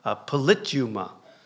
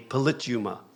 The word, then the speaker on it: politeuma: And if you look it up, it's transliterated, like in Strong's dictionary and some of the English dictionaries from the Greek, it's transliterated as polituma. And you spell it P-O-L-I-T-E-U-M-A, polituma, is how it's transliterated into the English dictionaries.